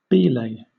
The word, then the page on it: belay: British English